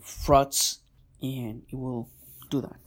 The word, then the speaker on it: Frotz: FROTZ. That's how it's spelled. Frots. FROTZ. Okay.